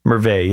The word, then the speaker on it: Merveille: Merveille, yeah. That's how we say it, because we're used to having French in the country with the E-I-L at the end. You don't actually pronounce it.